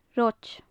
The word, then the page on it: roid: gaelic